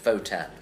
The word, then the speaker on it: Wotan: Why is Wotan pronounced Votan? – German.